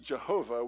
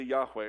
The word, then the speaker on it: Yahweh: It's also pronounced Jehovah, which is kind of a Middle Ages pronunciation where they stuck in different vowels, and that's not how it was pronounced. But most say it's probably Yahweh or something like that.